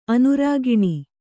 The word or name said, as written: ಅನುರಾಗಿಣಿ